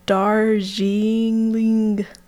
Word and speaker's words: Darjeeling: The Darjeeling Limited.